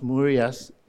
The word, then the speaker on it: murios: It's spelled Myrios, but it's pronounced Murias. It's where we get the word myriad.